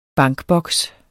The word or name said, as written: bankboks